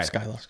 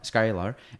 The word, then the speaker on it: Skyelar: And pronounce that right? – Yeah. – Skyelar? – Okay. Yep, Skyelar. – Skyelar.